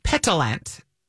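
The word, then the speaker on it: pétillant: Petillant. – Petillant. – It's like a pet gnat.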